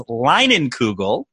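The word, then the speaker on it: Leinenkugel: I was chomping at the bit because the pride of Chippewa Falls is where it's made, and it is Lingenkugel, which is how you pronounce that.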